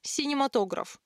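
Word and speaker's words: cinématographe: По-французски, кстати, слово произносится как «синематограф», а привычный нам вариант «кинематограф» — это калька с немецкого.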